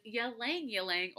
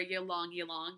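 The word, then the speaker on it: Ylang-Ylang: And last but not least, everyone's, I think, favorite fragrance to say, favorite oil to pronounce is Ylang Ylang or Ylang Ylang, depending on how you have heard it.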